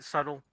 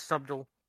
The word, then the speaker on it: subtle: That's why I'm trying to be very subtle, but unfortunately, subtle. That's subtle. The B is silent, subtle.